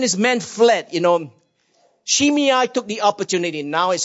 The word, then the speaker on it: Shimei: A man by the name of Shimei. Uh, the, you know, the Bible is spelled S-H-I-M-E-I. Uh, it can be pronounced Shimei, uh, but the correct spelling is Shimei, okay?